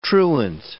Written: truant

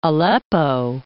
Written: Aleppo

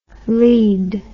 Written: leed

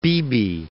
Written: Beebe

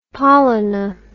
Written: Pollini

